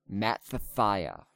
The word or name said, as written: Mattithiah